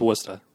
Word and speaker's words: Worcester: Wusta. – Yeah. I'm sorry. I'm just not from there. – Yeah. – Wusta. – It's hard to pronounce. It looks like Worcester. – Right, exactly. – No, it's Wusta.